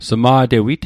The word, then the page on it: sma'tewit: Mi'gmaq